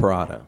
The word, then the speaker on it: Perrotta: Scared, but Tom Perrotta, Perrotta? Am I pronouncing it correct? – Perrotta.